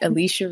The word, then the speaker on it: Alechia: Well, y'all can find my goofy self at Alechia Reese everywhere, because I did not want to make it complicated.